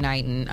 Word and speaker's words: Knighton: And we actually just had her brother on the show not that long ago, Willie Knighton, McKnighton, I'm sorry. Knighton. Yeah, I said it wrong the first time, yeah.